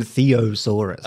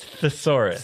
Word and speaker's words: thesaurus: Did I say it wrong? – I think you said theosaurus. – Oh, it's thesaurus.